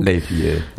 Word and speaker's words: Les Vieux: Am I pronouncing it right? – Yeah. – His song about growing... – Les Vieux, yeah.